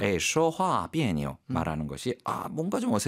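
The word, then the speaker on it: 비에뇨 (别扭): 역시 그 니오자의 발음 경성으로 읽는다는 거 가볍게. 그래서 비에뇨.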